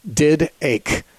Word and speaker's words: Didache: Didache is spelled like the word didache, in a D-I-D-A-C-H-E. It's Didache.